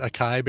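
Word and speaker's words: açaí: I think that's how I was told because I used to pronounce it acai berries. – Yeah, but it's acai berries. – Acai berries. – I believe somebody in the chat room is going to acai. Yeah, okay, so it's three syllables.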